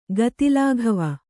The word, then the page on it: ಗತಿಲಾಘವ: gati lāghava